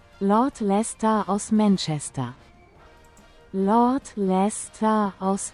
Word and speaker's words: Leicester: So, wie spricht man das aus? Los, komm, erzähl mal. Wo ist das? Hier ist das. Los, erzähl mal. – Lord Leicester aus Manchester.